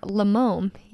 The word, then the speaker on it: Lemome: It's called, the brand is, how would you say that? Limome? – Yes, Limome. Yeah. – Limome. – Limome. – L-E-M-O-M-E.